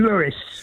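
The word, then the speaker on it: Louis: But Tim, Satchmo pronounced his name Lewis.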